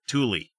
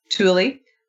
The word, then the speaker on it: Tooele: M-A-N-T-U-A. – Mantua. Tooele. Tooele is a fun one. – Thule. – Thule.